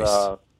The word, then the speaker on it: Boyce: Is it spelled like Boise, like in Idaho? – Or voice, as in the voice? – Boyce.